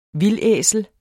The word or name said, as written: vildæsel